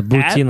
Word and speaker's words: Boutin: B-O-U-T-I-N. Hey, you can follow him on Twitter. – So Boutine like poutine. Sorry. Go ahead. – At R Boutine, B-O-U-T-I-N-J-D-Q.